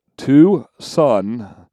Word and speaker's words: Tucson: Tucson, or should we say Tucson, is the second most misspelled city in the state. They don't tell us what the first is. It is pronounced Tucson, which makes everything a little bit more confusing.